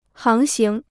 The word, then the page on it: 航行: háng xíng